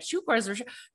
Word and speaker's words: chakras: I think they say it like chukras, but these are also people who live in America.